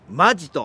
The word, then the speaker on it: 本気: 今回はですね、この時期の恒例イベントとなりました、本気と書いてマジと読みます南魚沼マジ丼でございます。